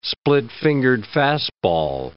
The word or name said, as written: split-fingered fastball